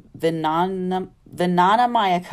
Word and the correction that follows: Vijnanamaya: Kosha, that's spelled, I'm probably even pronouncing it crazy, but it's Vijnanamaya.